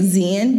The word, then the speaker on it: Xian: Miguel was allegedly assaulted a University of New Mexico student named, I'm going to say it, Xian Bass. But it's spelled X-I-A-N if you want to look that up.